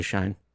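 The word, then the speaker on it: Shein: I did not know it was Shine.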